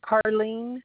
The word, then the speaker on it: Karlene: Carlene is spelled K-A-R-L-E-N-E. Last name is Kohlberg, C-O-L-B-E-R-G.